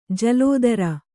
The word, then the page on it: ಜಲಾಧಾರ: jalādhāra